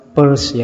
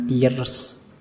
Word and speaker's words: Peirce: Itu bacanya Peirce ya, jangan Pierce. Ya, kayak kalau kalian baca dompet, P-U-R-S-E, Peirce. Jadi lebih seperti itu membacanya.